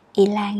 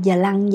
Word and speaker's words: y lan: các bạn nghe đọc là y lan thì đọc sao sẽ viết vậy luôn nha chữ y sẽ là y dài y lan y lan à, không có đọc là giờ lăng giờ lăng nha